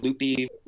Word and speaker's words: Lupe: The Dallas County Sheriff Lupe Valdez, who pronounces her name Lupe,